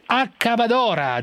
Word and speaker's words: Accabadora: Autrice di Accabadora, giusto la pronuncia? – Giusto.